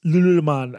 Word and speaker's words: Lululemon: Are you familiar with the brand Lululemon, sometimes mispronounced Lululemon? – Lululemon. It's like yoga pants and stuff. – Yeah. – Cool. Nailed it.